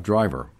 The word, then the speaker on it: DRIVR: I'm here today on show number 244 with Jared Williams and Nick Seitz, who was one of the co-founders of DRIVR.